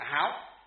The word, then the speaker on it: háo: Chữ này nó là chữ hảo, nhưng mà khi đọc là gì? Đọc là sang tiếng Việt Nam là gì? Là háo, háo sắc.